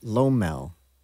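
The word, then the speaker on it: LOML: Acronym for love of my life, a soulmate, pronounced Lomel.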